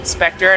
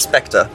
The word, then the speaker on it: Spectre: So, just for the record, you pronounce it Spectre. It's not Spectre.